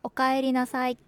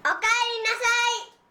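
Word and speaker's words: おかえりなさい: لنرى كيف تنطق هذه العبارة في الحوارات اليومية. – أوكايرينساي. – أوكايرينساي. – أوكايرينساي.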